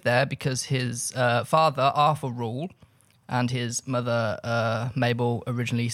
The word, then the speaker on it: Reuel: Full name, John Ronald Rule Tolkien. – Rule. – R-E-U-E-L. – Rule. – I was not 100% how to pronounce it. Rule.